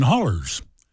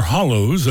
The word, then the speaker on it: hollow: And country folks, especially those from the mountains like where Randy Travis and me are from, understand hollers. Or hollows, I guess the correct pronunciation.